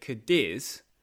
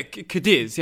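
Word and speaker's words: Cádiz: And you made an effort to say it properly. She went, oh, yeah, Cadiz, yeah. And yes. – No. – Yes. – No, not Cadiz.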